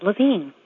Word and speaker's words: Levine: Sometimes people call me Ellen Levine, which is not how I pronounce it. But a friend said, well, at least it rhymes with divine.